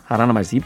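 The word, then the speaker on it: Alannah Myles: 자, 알아나 마일스, 아라나 마일스. 이 발음이 참 힘들어요. N자가 두 개 들어가 있는데 아라나 마일스, 네.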